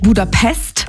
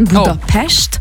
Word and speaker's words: Budapest: Also erstens appreciaten wir das schon mal, wenn man nicht Budapest sagt, sondern Budapest. – Oh. Okay, weil das S immer ausgesprochen wird wie ein Sch.